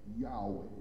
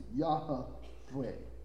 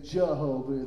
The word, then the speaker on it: Yahweh: Now that I spent plenty of time on that, just for the record, Yahweh is how it's pronounced. Y-A-H and then H-O and then V-A-W. That's how you pronounce it. Yahweh. Okay? There's no J, so we understand when they say Jehovah, it's actually what? Yahovah. Right? Or, if you slow it down and pronounce it right, Yahweh.